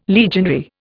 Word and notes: legionary: Indian

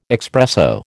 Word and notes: expresso: American English

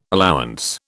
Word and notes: allowance: British English

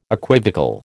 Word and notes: equivocal: American English

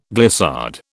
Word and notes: glissade: British English

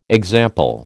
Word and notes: example: American English

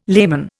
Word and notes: layman: Indian

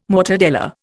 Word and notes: mortadella: Indian

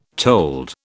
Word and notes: told: British English

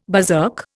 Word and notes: berserk: Indian

